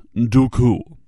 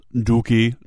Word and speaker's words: Dooku: My name is pronounced Dooku, not Dookie, not D to the oo, K to the oo.